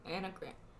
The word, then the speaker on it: Enneagram: But if it's a number, if you don't really understand or, like, know a lot about the, how do you say it, Enneagram? – Enneagram.